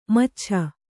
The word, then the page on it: ಮೈಚಾಚು: mai cācu